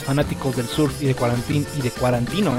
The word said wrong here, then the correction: Tarantino: ¿eh? ¿Quién es ese? No. – No mames. – Ah, cálmate, solo lo pronuncié mal una vez. Bueno, de Quentin Tarantino.